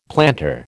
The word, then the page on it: planter: American English